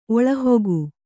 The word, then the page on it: ಒಳಹೊಗು: oḷahogu